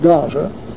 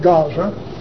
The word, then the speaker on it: Gaza: All right, thus saith Jehovah, for three transgressions of Gaza, I used to pronounce Gaza, but I hear all the television, they always pronounce it Gaza. So I guess the Hebrews, the Jews pronounce it Gaza. So if I sound like I'm trying to put on the calling it Gaza, all I'm trying to do is pronounce it like they do today.